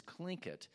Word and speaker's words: Tlingit: whose background is Tlingit, And although uh, doesn't sound the way it's spelled, it's spelled T L I N G I T.